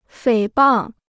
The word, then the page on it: 诽谤: fěi bàng